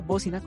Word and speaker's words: Bócina: como La Bocina con acento en la O y con C. También nos encuentras en Instagram como La Bocina Cruz, R-E-W, como con doble N, doble S y guión bajo, el guión bajo.